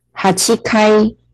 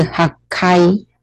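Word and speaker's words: 八階: 那再来呢，就是八楼呢，它有两个读音 hachikai 或者是 hakai，两个都可以，hachikai 或者是 hakai，两个都可以。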